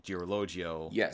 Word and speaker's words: Girologio: And I'm really impressed that you were able to take the time between our phone call conversation earlier and now to work on the pronunciation so that you could say Girologio without having it come out as like a knotted lump of syllables.